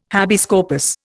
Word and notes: habeas corpus: Indian

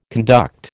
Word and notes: conduct: American English

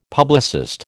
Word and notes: publicist: American English

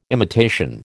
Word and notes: imitation: American English

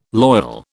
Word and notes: loyal: British English

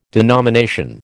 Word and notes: denomination: American English

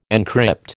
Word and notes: encrypt: American English

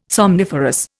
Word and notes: somniferous: Indian

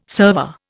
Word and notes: server: Indian

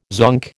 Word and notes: zonk: American English

